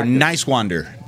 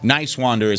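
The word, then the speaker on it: Niswander: How did we pronounce his name? – Nicewander. Nicewander is how you say his name.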